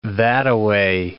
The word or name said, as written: thataway